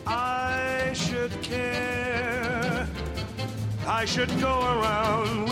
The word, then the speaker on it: should: Primero practiquemos oraciones con should. – Should se pronuncia con una sh suave y larga, como si se estuviera haciendo callar a alguien.